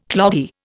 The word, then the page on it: cloggy: Indian